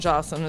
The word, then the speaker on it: Joslyn: And Jocelyn is spelled JOSLYN.